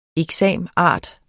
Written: exam.art.

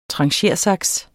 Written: tranchersaks

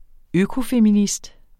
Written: økofeminist

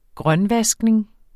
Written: grønvaskning

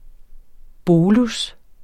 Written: bolus

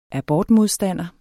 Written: abortmodstander

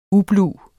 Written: ublu

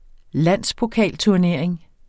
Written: landspokalturnering

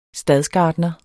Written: stadsgartner

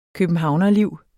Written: københavnerliv

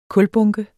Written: kulbunke

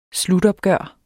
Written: slutopgør

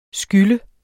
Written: skyld

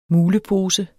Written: MULEPOSER

